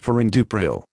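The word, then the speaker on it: perindopril: This is the consumer medicine information for Purindo 2, Purindo 4, Purindo 8, Purindopril, pronounced Purindupril.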